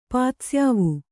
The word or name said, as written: ಪಾತ್ಸ್ಯಾವು